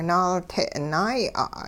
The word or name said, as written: naalte' naaya'